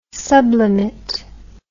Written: sublimit

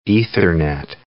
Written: Ethernet

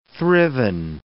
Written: thriven

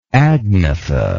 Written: agnatha

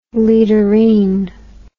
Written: leaderene

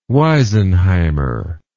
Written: weisenheimer

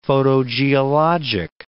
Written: photogeologic

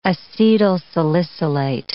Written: acetylsalicylate

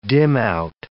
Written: dimout

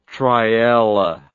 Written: triella

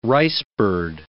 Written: ricebird

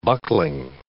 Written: buckling

